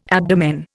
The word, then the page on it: abdomen: Indian